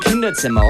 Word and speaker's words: Kinderzimmer: K-I-N-D-E-R-Z, die Doppel-M-E-R. Ausgesprochen Kinderzimmer und es kommt noch viel, viel mehr. P-R-U-D-C-T-I-O-N-S.